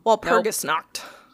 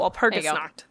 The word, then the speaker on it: Walpurgisnacht: Walpurgisnacht. There we go. – Walpurgisnacht.